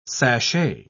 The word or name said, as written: sashay